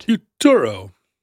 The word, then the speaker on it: Futuro: Did I say that right? – Futuro. – I said it like Arturo.